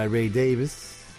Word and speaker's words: Davies: Till the end of the day, the very cool Kinks from their third album, Kink Kontroversy, written by Ray Davies, as he likes it pronounced now, and produced by Shel Talmy.